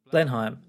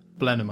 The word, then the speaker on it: Blenheim: Blenheim or something it's called? Blenheim? Yeah, Blenheim. – Blenheim, it might be pronounced. Yeah, that's in Oxfordshire.